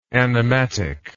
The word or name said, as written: animatic